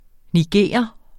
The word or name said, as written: nigerer